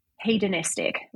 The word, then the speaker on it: hedonistic: could be hedonistic, right? Is that how you pronounce it? Mm-hmm.